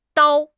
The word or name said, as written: dao1